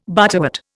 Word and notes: butterwort: Indian

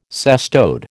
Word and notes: cestode: American English

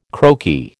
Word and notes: croaky: American English

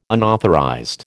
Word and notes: unauthorized: American English

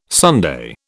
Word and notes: Sunday: British English

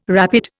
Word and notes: rapid: Indian

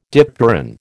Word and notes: dipteran: American English

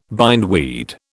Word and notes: bindweed: British English

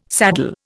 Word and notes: saddle: Indian

